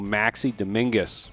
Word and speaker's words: Maxi Dominguez: Got a big crew joining me today, Matt Gindor from WTA, Brian Haywood, and also Maxie Dominguez.